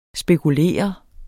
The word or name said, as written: spekulere